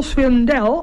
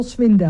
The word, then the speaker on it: Swindell: Ja, geen Cole Swindell. – Nee, Cole Swindell. – Komt de klemtoon. Ja, de klemtoon die hebben sommigen anders dan dat wij denken.